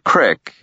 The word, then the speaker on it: creek: He reached a large stream, of the kind the locals called a creek and pronounced crick, and decided to follow it.